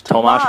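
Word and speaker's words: Tomáš: Actually, speaking of Hurdle, if we're looking back at a little reflection of December, Thomas Hurdle is freaking good at hockey. – Tomas Hurdle, whatever. – Now I'm just going to point out every time you say a player's name wrong.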